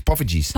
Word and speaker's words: poffertjes: It's poffertjes.